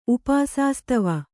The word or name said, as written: ಉಪಾಸಾಸ್ತವ